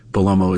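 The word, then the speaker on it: Bellomo: I do take for granted sometimes that Belomo is an easy name to pronounce and to spell.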